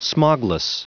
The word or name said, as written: smogless